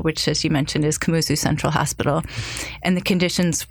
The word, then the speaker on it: Kamuzu: Talk about your work at what KCH is, which is the Kamuzu Central Hospital, correct? – Yeah.